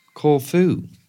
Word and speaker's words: Corfu: And I don't think anybody's ever pronounced it Corfu before. – Corfu. – Is that similar to Corfu? – Corfu, darling. That's where I'm going.